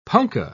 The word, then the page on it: punka: British English